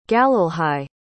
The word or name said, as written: galilhai